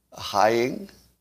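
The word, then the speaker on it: Heying: Give me the proper pronunciation, because I know I'm getting both of their names wrong. Haying, okay. And his first name is Heather, right? Haying. All right, apologies to all people whose names I say wrong.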